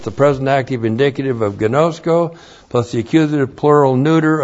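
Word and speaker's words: gnosko (γινώσκω): G-I-N long O-S-K long O, gnosko.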